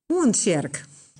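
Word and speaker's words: Oentsjerk: Ik snap dat je een beetje moeite hebt met Oenstjerk uitspreken, maar voor de rest was het helemaal goed. – Ik kon het ook nergens vinden online van hoe spreek je dat uit? Weet je, dan kon ik oefenen vooraf. Maar kan je het nog één keer zeggen? – Oenstjerk.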